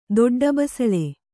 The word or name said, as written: ದೊಡ್ಡಬಸಳೆ